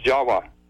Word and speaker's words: Java: The word in Java, the island of Java, you know, is pronounced Jawa.